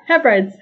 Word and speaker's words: Hebrides: Is that how it's pronounced? – The Outer Hebrides? Yeah. – I always thought it was the Hebrides. – There isn't that extra E. – Okay, the Hebrides. Good to know. – Yeah, Hebrides.